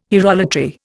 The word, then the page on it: hierolatry: Indian